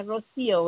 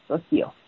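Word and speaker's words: Rocío: Yeah, Rocio was the accent on the I. Yeah, Rocio.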